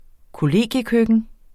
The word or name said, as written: kollegiekøkken